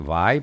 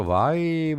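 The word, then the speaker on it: Vai: Και λέει Βάι προφέρεται αυτό. Βάι Μπραζίλ.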